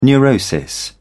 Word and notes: neurosis: British English

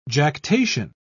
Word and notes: jactation: British English

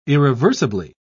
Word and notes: irreversibly: British English

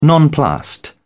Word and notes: nonplussed: British English

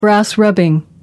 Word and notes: brass rubbing: American English

